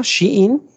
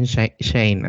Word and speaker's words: Shein: o Chain, como dice la raza. ¿Cómo se dice? Shein. Se suponía que era Shein, pero pues ahora todos le dicen Shane, así que pues X.